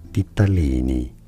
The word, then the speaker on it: ditalini: It is said as ditalini.